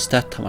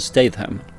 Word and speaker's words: Statham: Och till alla ute så heter han inte Jason Statham, han heter Jason Statham. Men eh, vi får förlåta Thomas för hans uttal.